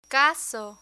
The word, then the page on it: caso: spanish